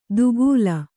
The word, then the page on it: ದುಕೂಲ: Kannada